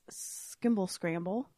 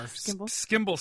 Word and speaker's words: Skimble Scamble: What's it going to take to get you into this Skimble Scramble? Did I say that right? – There's no R. Skimble Scamble.